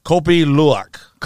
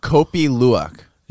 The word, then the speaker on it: Kopi Luwak: Kopi Luwak. – Kopi Luwak.